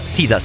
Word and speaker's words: Cedar: Cedar, que poder els més experts ja sabeu com... qui són? La Tània està enfadada ara mateix perquè es diu, es pronuncia Sida, saps?